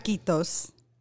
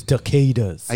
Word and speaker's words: taquitos: It's taquitos, not toquitos. – Sorry, Beaner Extraordinaire. – It's taquitos.